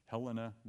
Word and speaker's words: Helena: We had people come from, I think it's pronounced Helena, Montana.